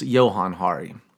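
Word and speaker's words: Johann Hari: Johan Hari, as I've been saying his name, is actually pronounced Johan Hari.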